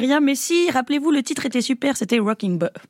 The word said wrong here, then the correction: Wrecking Ball: J'ai très mal prononcé. Wrecking Balls.